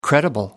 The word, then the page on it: credible: American English